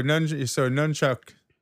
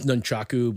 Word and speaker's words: nunchaku: So nunchuck. Am I saying that right? Nunchuck? – Well, it's nunchaku, but yeah. Yeah. – What is it? – Nunchaku. Yeah, but nunchuck is fine.